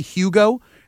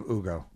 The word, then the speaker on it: Hugo: Some people say Hugo, and some people say Ugo. They like don't pronounce the H. And if you remember, that's how the commissioner actually announced it on the podium. Maybe when we talk to Scal at some point, I need to get to the. – Is it Ugo? I think it's the proper Spanish pronunciation of Ugo.